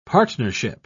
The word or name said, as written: partnership